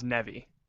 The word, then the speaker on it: Neve: You didn't, but it's fine. It's the most common mispronunciation. My last name is pronounced Nevy.